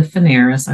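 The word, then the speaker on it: Fanaras: So again, my name is Linda Fanaris. I like to spell it because it's always misspelled, but it's F as in Frank, A-N-A-R-A-S.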